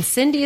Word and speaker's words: Sindy: And Sindy is spelt with an S, and my name is spelt with an S.